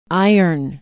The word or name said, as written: Iron